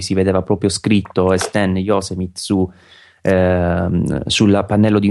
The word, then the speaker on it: Yosemite: Sì, si legge Yosemite. Fa... So che quando sì. ci sono stato là in zona avevo proprio indagato su quale fosse la pronuncia corretta, è Yosemite. – Yosemite, perfetto.